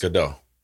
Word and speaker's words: Gal Gadot: Gal Gadot.